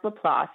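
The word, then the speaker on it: LaPlace: Yeah, it's pronounced La Place.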